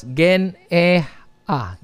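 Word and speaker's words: gen-e-ha (γενεά): Which is pronounced as gen-e-ha, gen-e-ha.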